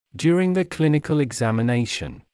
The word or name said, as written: during the clinical examination